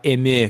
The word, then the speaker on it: Dotemu: Up to four players can play locally or online in this bodacious game developed by Tribute Games and published by Dotemu. It's .emu, isn't it? I said that name wrong. Actually, it's .emu because it's French.